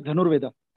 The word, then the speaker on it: Dhanurveda: And when you see the history in India, you know, with Dhanurveda, how do you pronounce it properly, the art of archery? There's this book, Dhanurveda. – It is Dhanurveda. – Dhanurveda, exactly. Dhanurveda.